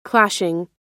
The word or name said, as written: clashing